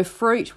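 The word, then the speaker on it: Froute: So Fruit, which is spelt F-R-O-U-T-E, F-Root, pronounced Fruit, began in East Gippsland a few years ago as a project which was producing art related to fruit.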